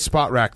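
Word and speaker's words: Spotrac: I think it's Spot Rack, the place where you can look up all the contracts.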